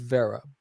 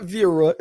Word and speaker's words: Vera: It's V-E-R-A. It's pronounced Vera. But in school, my teachers would always say Vera. They would somehow add an extra letter in there. It's like, it's not Vera, it's Vera.